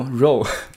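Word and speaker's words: Ro: 对对对，这个也是我的一个同事，很多人不知道该怎么读他的那个读音，因为主播的名字叫 R O Row。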